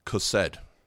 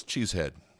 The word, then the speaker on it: chesed: It is the Hebrew word chesed, and it's spelled C-H-E-S-E-D-H, okay? It almost looks like it says cheesehead, but it's not. It's chesed, okay?